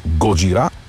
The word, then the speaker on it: Годжира: Позволявам си да произнасям с френското ударение на последната сричка – Годжира.